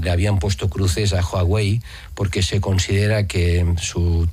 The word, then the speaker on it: Huawei: Se dice así, ¿no? Huawei, ¿no? – O Huawei. – ¿Eh? – O de chino Huawei. O de justita. – No. Huawei. – Como de sueco. – Igual. Huawei, ¿no?